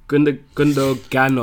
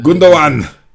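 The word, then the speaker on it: Gündoğan: So you're backing up what Gundogan or Gundogan? – Gundogan. Gundogan. – It's Gundogan, how it's spelled.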